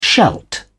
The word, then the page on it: shalt: British English